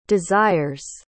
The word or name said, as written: desires